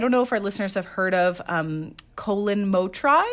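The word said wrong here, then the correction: Mochrie: I believe it's pronounced Colin Mockery.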